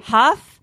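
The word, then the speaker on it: Hough: This is sad relationship news having nothing to do with the relationship, but I don't really know how to pronounce his last name. Is it Derek Huff? – Huff.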